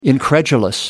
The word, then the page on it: incredulous: American English